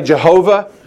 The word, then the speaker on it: Yahweh: Some say Jehovah. Some say Yahweh. I think Yahweh is the better pronunciation of the Hebrew, the name of God, His name there revealing Himself.